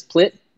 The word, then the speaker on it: Plitt: It's Jeff with a G, G-E-O-F-F. And the last name is Plitt. Easy to remember because it rhymes with shit. P-L-I-T-T. And so I'm Jeffrey Plitt on Twitter, Jeff_Plitt on Instagram.